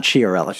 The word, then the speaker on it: Chiarelli: That's either a Shil- is it Chilarelli? Is that how you say his name? – Sh- uh, Chiarelli. Chi- Peter Chiarelli. Chiarelli, yeah.